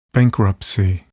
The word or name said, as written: bankruptcy